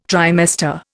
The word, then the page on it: trimester: Indian